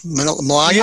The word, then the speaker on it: Malaga: Malaga? – Malaga.